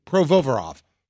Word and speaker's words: Provorov: How do you say his name? Provorov. Did I get that right? Provorov. That's it.